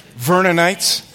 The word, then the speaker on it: Vernonites: And can you imagine what God would do if 50,000 Vernites, is that how you say it? Vernonites would show up and say, "Read us the Bible!